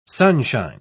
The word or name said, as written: sunshine